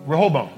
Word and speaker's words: Rehoboam: How do you pronounce it? Rehoboam, okay. And so I want you to know about this because this is Solomon's son.